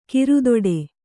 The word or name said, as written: ಕೋರೆದಾಡೆ